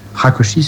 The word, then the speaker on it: Rákosi: C'est donc Rákosi, c'est comme ça qu'on prononce, c'est bien ça, qui va diriger la Hongrie d'une main de fer.